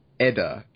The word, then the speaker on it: Edda: And I think it's pronounced Edda, E-D-D-A.